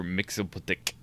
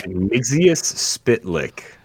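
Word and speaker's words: Mxyzptlk: I'm pretty sure they said Mixius Spitlick. Like you're mixing a bowl, you spit and lick.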